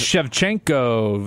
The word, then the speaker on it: Shevchenko: I can't even pronounce it. – Shevchenko, Valentina Shevchenko. She's great.